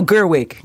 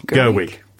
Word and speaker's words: Gerwig: I can't remember how you pronounce Gedwig. – It. – Gedwig, I think it's Gedwig. – It's something like that, yes. – No, Gerwig. – Gerwig, thank you.